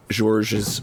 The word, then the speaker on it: Georges: So I will always take an opportunity to talk about something as strange and interesting as fun as Georges. I can never pronounce his first name. The S at the end of Georges always messes me up.